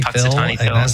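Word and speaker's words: Punxsutawney Phil: Puxatawny Phil. – Puxatawny Phil.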